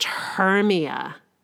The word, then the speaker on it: Hermia: "Her name is pronounced Hermia."